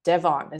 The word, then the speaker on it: Devon: inhaling hailing from the stunning landscapes of Devon. Is that how I pronounce it? That's it.